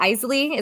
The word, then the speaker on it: Isley: How do you say their name? The Isley? Is that how you said it? – Yes.